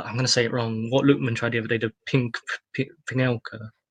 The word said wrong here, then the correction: Panenka: Yeah, the Panenka, yeah, the loopy one.